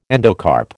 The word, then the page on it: endocarp: American English